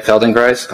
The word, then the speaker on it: Feldenkrais: So going on Feldenkrais, did I say that right? Feldenkrais? Okay. – You did.